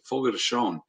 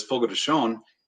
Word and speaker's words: Fogo de Chão: And Hobbs told me how to say this correctly. Fogo de Chão. I always thought it was Fogo de Chão. – That's what I thought. – Call it that, but it's Fogo de Chão.